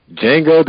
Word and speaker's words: Django: Django the D is silent.